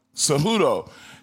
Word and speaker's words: Cejudo: Henry Cejudo? I can't say his name right. Say it again. – Cejudo.